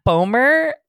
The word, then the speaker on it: Bomer: I had a dream like that once that I was dating, even though I know he's gay, Matt Bomer.